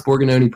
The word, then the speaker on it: Borgognoni: First time they referenced it, I didn't know what they were talking about, but you pronounce Borgognoni perfectly, so I already like you guys.